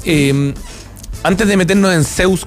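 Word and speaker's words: Zeuz: Como lo puse así con, con esa pronunciación porque en verdad es con doble Z. Z-E-U-Z. E Zeus. No, sí, en la, en la, está bien en la mención, Es Zeus. Es Zeus.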